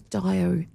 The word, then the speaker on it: diarrhea: I thought it was diarrhea. – Well, you don't hear. There's actually two. There's two silent letters in the word diarrhea.